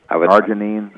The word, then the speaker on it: arginine: I've read recently that they've been doing studies with L-arginine, I think is mm-hmm. how it's pronounced. – Correct. – Yes. – Arginine.